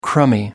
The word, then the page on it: crummy: American English